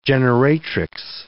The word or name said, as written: generatrix